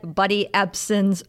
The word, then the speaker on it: Ebsen: that I said Buddy Ebsen's name wrong. I called him Buddy Epstein, unfortunately.